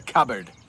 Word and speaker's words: cupboard: You keep it in the cupboard. And in Maine, we say cupboard, but it is pronounced cupboard, cupboard, cupboard.